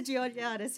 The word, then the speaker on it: Georgiadis: Is it Georgiadis? Yes. Costa Georgiadis, who engages so many people with his own passion for gardening.